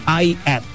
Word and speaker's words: IATT: Nama mereka ini inisial begitu ya, ada huruf I, ada huruf A, ada T dan T. Cuman spellingnya atau bukan spellingnya, pronounce-nya atau cara bacanya itu adalah I at begitu ya.